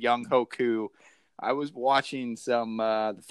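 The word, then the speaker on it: Younghoe Koo: I mean, you got to go Young Ho Koo. I mean. – Yeah, Young Ho Koo, correct.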